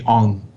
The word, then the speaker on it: Aung: Yeah, it's Aung San Suu Kyi. – Okay. – And Aung, it's like Anne, but it's A-U-N-G, Aung.